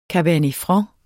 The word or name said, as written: franc